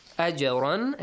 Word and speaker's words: أَجْرًا: أَجْرًا actually is pronounced with an echoing sound.